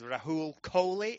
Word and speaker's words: Rahul Kohli: That is correct. You pronounced it wrong on stage. – I did. – You said Rahul Kohli, and I was like, that's not my fucking name.